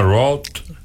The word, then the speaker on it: Roth: Se llama Roth. – Roth, ajá, se escribe R-O-T-H.